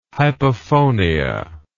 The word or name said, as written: hypophonia